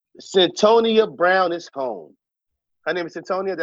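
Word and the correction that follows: Cyntoia: Did I say that right? – Centoya.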